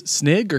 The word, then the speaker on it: Snygg: Matthias Snig or Snig? Yep. S-N-Y-G-G. – You're correct. – Sure. Well, whatever pronunciation you say, I agree. – Yeah, it's definitely that. – Oh, Sniggy. – You definitely said it right.